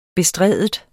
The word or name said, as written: bestride